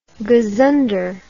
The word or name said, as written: gazunderer